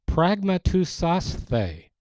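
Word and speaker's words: pragmateusasthe: Pragmatousasthe is the Greek word. Pragma, P-R-A-G-M-A, tousasthe, T-E-U-S-A-S-T-H-E.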